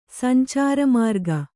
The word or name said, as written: ಸಂಚಾರಮಾರ್ಗ